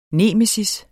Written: nemesis

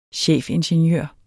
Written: chefingeniør

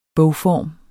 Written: BOGFORM

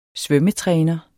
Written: svømmetræner

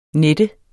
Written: nette

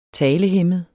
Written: talehæmmet

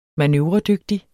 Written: manøvredygtig

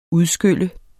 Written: UDSKYL

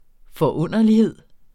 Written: forunderlighed

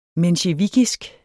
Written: mensjevikisk

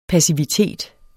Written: passivitet

